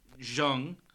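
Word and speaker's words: Zheng: Right, the Zong, Which is which is uh, Chinese pronounced Zhong, uh, which means steam.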